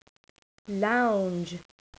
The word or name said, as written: lounge